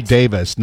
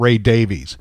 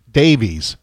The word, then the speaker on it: Davies: Ray pronounces it Davis. It's Ray Davis, not Ray Davies. – Right. – But Dave pronounces it Davies.